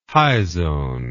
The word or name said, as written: hyzone